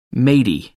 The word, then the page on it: matey: American English